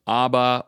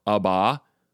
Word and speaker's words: Abba: can be pronounced Abba or Abba, although you're pretty much stuck with Father on the back end.